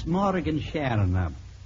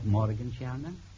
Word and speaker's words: Morgenstierna: Good evening, Miss Morgenstierna. Am I pronouncing it right, Morgenstierna? – That's right, Mr. Bergen.